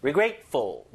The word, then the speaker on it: regretful: Regretful, regretful, 뭐 grateful, thankful, thoughtful 이런 거 있듯이 regretful, regret, regretful, regretful. I feel regretful. 아, 좀 후회스러워. 그죠? 후회스러워. I feel regretful, regretful. 강세 잘 보세요. Regretful.